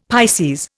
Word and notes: Pisces: Indian

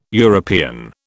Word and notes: European: British English

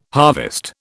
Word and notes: harvest: British English

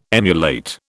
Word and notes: emulate: British English

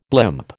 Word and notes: blimp: American English

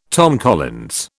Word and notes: Tom Collins: British English

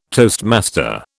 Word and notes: toastmaster: British English

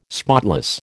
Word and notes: spotless: American English

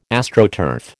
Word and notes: Astroturf: American English

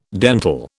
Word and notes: dentil: British English

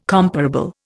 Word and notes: comparable: Indian

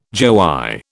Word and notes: joey: British English

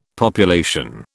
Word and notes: population: British English

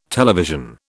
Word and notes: television: British English

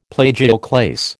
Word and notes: plagioclase: American English

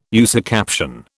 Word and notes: usucaption: British English

